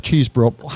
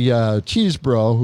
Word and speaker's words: Chesebro: Yes, just like cheese and bro. Not Chesebro, Chesebro. No, Chesebro.